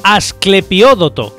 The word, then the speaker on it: Asclepiódoto: Es el día de Aicardo, Albino, Alpino, Apro, Asclepiódoto, con acento en la primera O, Asclediópoto.